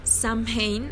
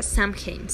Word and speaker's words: Samhain: El 31 de octubre celebramos Samhain o Samhain, acuérdense, no se pronuncia Samhain, se pronuncia Samhain, pero popularmente pues lo conocen como Samhain pero si eres güey, casaman.